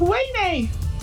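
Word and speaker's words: Ouaisné: We live next to a place that's spelt O-U-A-I-S-N-E, but it's pronounced Wayne.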